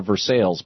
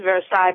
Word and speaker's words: Versailles: Is it Versailles or Versailles, Pennsylvania? – Well, the French word pronunciation is Versailles, but it's Versailles here in Pennsylvania.